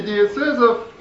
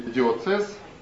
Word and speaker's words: диецез: Правители диецезов назывались викарии, викарии и диецезум, или экзархи. Это все я говорю про светскую терминологию. Видите, вся церковная терминология, не вся, конечно, но вот диецез, например, или диоцез, сегодняшнее произношение, тоже взята из гражданства.